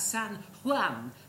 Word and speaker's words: San Juan: Oh, that's San Juan and San Jose. In Spain, J's are pronounced like H's.